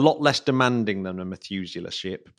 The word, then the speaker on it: Methuselah: The Methuselah Ship, did I pronounce that right? – Yeah, the Methuselah Ship.